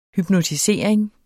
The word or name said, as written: hypnotisering